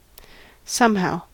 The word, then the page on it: somehow: American English